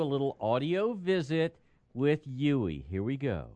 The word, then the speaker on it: Yui: Spelled Y-U-I, but pronounced Yui. – Y-U-I.